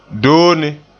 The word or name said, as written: doone